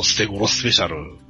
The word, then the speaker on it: 捨て頃スペシャル: もうその、捨て頃スペシャル。素手喧嘩 SP と書いて、捨て頃スペシャルですよ。